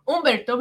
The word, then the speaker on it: Humberto: Humbert, Humberto, Humberto, because we don't pronounce the H. Just FYI, everybody, don't say Humberto.